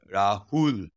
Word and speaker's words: Rahul: Yeah, the Rahul.